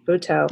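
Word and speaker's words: Butoh: I know there was a production of Euripides, The Trojan Woman, performed in the Japanese dance art form called, is it Buto? Buto? How do they pronounce that? – Buto.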